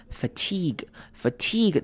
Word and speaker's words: fatigue: Fatigue，这个字要注意的是中间的 I 念长母音 E 的声音，fatigue，然后 G-U-E 就念 G 的声音，fatigue。